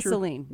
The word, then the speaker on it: Ghislaine: but Is her name pronounced Ghislaine Maxwell Crasher? – Ghislaine. – Ghislaine Maxwell. – Isn't it? – Yeah, Ghislaine, yeah, right.